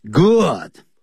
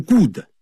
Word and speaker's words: good: Good, 好，非常的棒。不要念成 good。 Good, 跟书本 book 的发音一样。